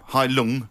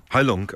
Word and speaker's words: Heilung: Heilung. – Heilung.